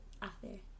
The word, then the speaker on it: hace: La hace. So you don't say H.